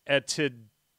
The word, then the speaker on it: Etidorpa: The book is called, and this is really hard to pronounce because it's uh, Aphrodite backwards, but it's Etidorpa